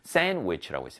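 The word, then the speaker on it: sandwich: 자, 그런데 이게 있는 건 좋은데 우리 친구들 이 샌드위치 읽을 때 D는 묵음입니다. 샌드위치라고 읽지 않습니다. 샌드위치라고 있습니다. 샌드위치. 그렇죠. D 발음은 없다 생각하면 되겠습니다.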